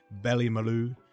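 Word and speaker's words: Ballymaloe: Go look out for Sophie's diary from Ballymaloo. Is that correct pronunciation? – It is, yes.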